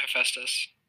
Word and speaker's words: Hephaestus: Yeah, Hephaestus.